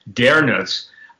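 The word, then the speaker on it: Dönitz: Now, one of the things people don't understand, towards the close of World War II, Reich Admiral, Donitz, D-O-N-I-T-Z, and some people pronounce it with the umlauts, Dernitz, told the world they weren't yet ready to accept or receive the secrets that the Nazis had basically come across.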